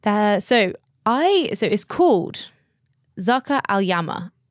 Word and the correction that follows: Zarqa Al Yamama: Zarqa Al Yamama.